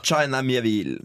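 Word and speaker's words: China Miéville: du eh, skal på livet ditt gjøres, uttale navn på en merkelig måte. China Mieville. Altså det, det, det, altså når man ser, det, det skal, China, som i den, den, den, engelske stavelsen av landet Kina, og så Mieville.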